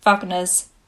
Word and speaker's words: Wagner: Now, obviously, you can have your traditional classics, like Canon in D Major or Wagner's Bridal Chorus.